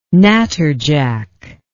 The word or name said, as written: natterjack